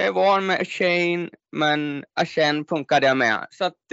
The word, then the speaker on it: Achane: Ja, det är Achane säger man nu i NFL. När han spelade på Texas A&M så var det alltid Shane.